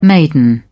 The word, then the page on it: maiden: British English